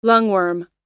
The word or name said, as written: lungworm